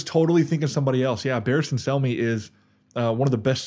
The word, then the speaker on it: Barristan Selmy: Barristan Selmy? Is that how you pronounce that? – Oh man. Yep.